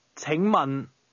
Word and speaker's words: 请问: 请问.